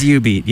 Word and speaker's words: jubeat: It's spelt with a J, but J-U-B-E-A-T. They actually pronounce it is U-Beat.